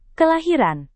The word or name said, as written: kelahiran